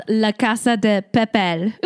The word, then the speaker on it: La Casa de Papel: It's called La Casa de Papel. Am I saying it right, Daniel? – Muy bien.